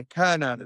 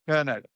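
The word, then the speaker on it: Kernow: Yeah, Kurnow. It's Kurnow. It's like Kurnow, the local ancient name for Cornwall.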